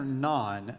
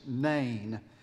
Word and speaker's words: Nain: Um, my wife asked me on the way this morning, uh, is that Nain or Nan? No, dear. Nan is Indian bread. It's spelled differently. It's Nain.